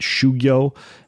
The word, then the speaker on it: shugyo: shugyo and pratyahara, and go ahead and slap me on the wrist if I'm totally butchering the pronunciation.